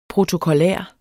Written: protokollær

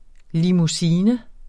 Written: limousine

